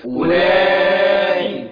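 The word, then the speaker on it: أولاء: ها أنتم أولئي تحبونهم. – خلي بالك هنا أولئي كلمة أولئي الواو لا تنطق، الواو لا تنطق. أولئي تتنطع إزاي؟ – أولئي، أقول أولئي هي باللون الرصاصي، يعني مش هتنطق.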